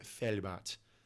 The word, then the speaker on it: Velbert: But in German, the V is pronounced like an F, which took me forever to figure out. But it's technically pronounced SSVG Velbert was the club.